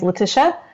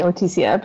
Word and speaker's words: Letitia: I mean, that's how you say it in Spanish, so that's okay. I usually say that that's okay. I usually, if I like meet someone for the first time, I usually say my name's Letitia. – Okay. – Just because that's in English. So it's usually typically easier for people, but I don't mind if you call me Leticia, because that's really should be how it's pronounced.